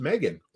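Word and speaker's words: M3GAN: Finally, we went to the movies the other night, and we went and watched Megan. – Yes. – So that is, for those who are wondering, the movie is, the name is spelled M3GEN.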